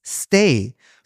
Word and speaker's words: Stage: Wo ich das ganze Jahr schon abkotze, weil der ja Stage geschrieben wird. – Genau. – Ja. Und ich mich immer wieder überrascht werde, dass sie den Stay aussprechen, aber es ist ja wohl richtig.